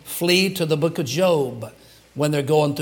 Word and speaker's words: Job: And so there it is, the book of Job, some people would say, but it's pronounced Job.